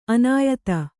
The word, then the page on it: ಅನ್ಯಥಾ: anyathā